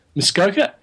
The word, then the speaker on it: Muskoka: Muskoka.